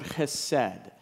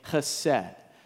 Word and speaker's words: chesed: And that is the Hebrew word chesed, or some pronounce it chesed, but I'm going to pronounce it today chesed. Why don't you say that word with me? Okay, ready? Chesed. Okay, let's say it again. You got to do the sound in your throat because Hebrew is a very guttural language.